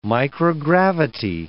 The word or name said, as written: microgravity